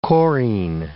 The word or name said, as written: chorine